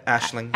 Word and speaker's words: Aisling: Aisling.